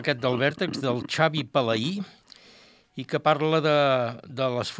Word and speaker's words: Palai: perquè està accentuada la I, és una... Suposo que deu ser així, no? S'ha de pronunciar Palai.